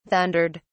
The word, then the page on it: thundered: British English